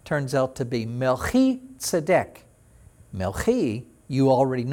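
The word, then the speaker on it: Melchi (מלכי): Melchi then is Melech with that little I sound on the end. Melchi is my king.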